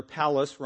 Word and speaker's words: Pallas: And Felix had an influential brother, Pallas, rhymes with Dallas, who happened to be best buddies with Claudius the Emperor.